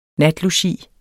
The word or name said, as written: natlogi